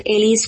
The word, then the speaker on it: Elli: It's called Elli's Kosher Kitchen and Elli is spelled E-double-L-I.